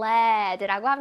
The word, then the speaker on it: lead: 그래서 납은 영어로 lead로 읽어요. 그래서 lead라고 읽기 lead가 lead라고 읽힐 때도 있지만 여기 연필심, 샤프심을 뜻할 때는 lead라고 읽힙니다. 이거 lead라고 하면 안 돼요.